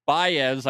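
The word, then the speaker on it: Báez: It's Javier Baez. Is that Baez, Ben? It's B-A-E-Z.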